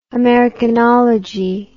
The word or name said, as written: americanological